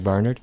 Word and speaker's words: Barnard: I accent the first syllable like Barnard.